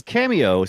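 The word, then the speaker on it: Kameo: Now tell me about is it Cameo or Cameo? I never knew. But it's that old game. No. It's an old game. – Yeah, it is. It's Cameo, spelled with a K.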